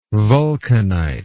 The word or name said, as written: vulcanite